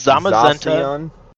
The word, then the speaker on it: Zamazenta: But speaking also of Nintendo Switch Lite, they also announced a Pokemon Sword and Shield Zamazetsu and Zacian Switch Lite edition. – Is it Wait, how did you pronounce that? It's Zamazenta.